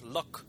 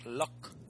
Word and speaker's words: Luc: Is it's actually pronounced Luc. I asked him. – Oh, because he's Le Français. – Luc.